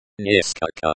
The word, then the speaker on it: несколько: Remember to pronounce несколько. Несколько, with a slight emphasis on the second syllable, скол.